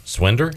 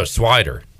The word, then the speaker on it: Swider: Swinder? – Swinder? – That's what I would say. Oh, Swider. – I was about to say there's no N in there.